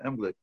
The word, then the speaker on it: Unglück: What's Unumglik? Did I say it wrong? – Yeah, misfortune, yeah. Unglück, yeah. – Unglück.